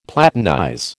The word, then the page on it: platinize: American English